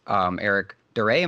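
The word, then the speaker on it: Eric Da Re: Eric Duray, am I pronouncing that right? – I believe so, yeah.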